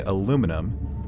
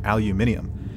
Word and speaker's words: aluminum: But it's just like, and, and I know that we have international listeners, but we have, here in America, we say aluminum, but I think like in, is it Australia, New Zealand, we also hear aluminium. – Aluminium, I feel, yeah. – I feel like you're adding like extra syllables and letters to that.